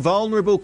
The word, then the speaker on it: vulnerable: Congratulations on pronouncing vulnerable correctly. Most people say vulnerable. Irks the hell out of me.